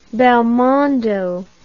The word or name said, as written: belmondo